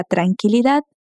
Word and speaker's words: tranquilidad: Fíjate que la palabra tranquilidad se pronuncia muy diferente en español. La U de qui no se pronuncia y termina en D. entonces no le colocamos ninguna vocal. Tranquilidad.